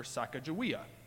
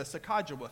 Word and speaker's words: Sacagawea: Now, depending on where you're from, uh, you pronounce it different. In fact, I grew up calling her Sacagawea. And then in, I think it's Cloverport, Kentucky, I went to the Sakajawa Festival.